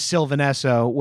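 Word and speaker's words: Sylvan Esso: K-H-U-R-A-B. I'm not gonna look it up because I'm lazy and stoned. And then the other one is Sylvanesso, which I know is how you say that, because I've been a fan of them for way longer, and they're fucking awesome.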